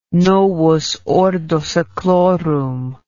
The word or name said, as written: novus ordo seclorum